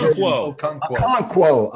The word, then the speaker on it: Okonkwo: Those not Okonkwo.